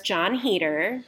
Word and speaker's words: Heder: It was shot on black and white film, 16mm, and it stars Jon Heder, who is Napoleon Dynamite.